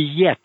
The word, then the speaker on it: Jet: Hennie, H-E-N-N-I-E. – Oh, really? – Yeah. Yeah. Huh. In Dutch, in Dutch, her name is, her nickname in Dutch would be Yet. But Yet is spelled J-E-T. So Yet wouldn't work so well here because people would pronounce it Jet, Oh, so, that's funny.